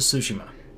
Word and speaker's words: Tsushima: The Ghost of Tsushima.